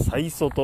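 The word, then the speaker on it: 差塩: えー、っとね、ここは、最祖パーキングエリアっていう、えー、塩、サに塩と書いて、差ってあの、差って、ギャップですよね、差。差っていう字に、えー、塩と書いて、最祖と読むそうです。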